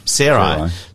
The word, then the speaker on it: Sarai: Sarai.